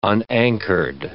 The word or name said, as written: unanchored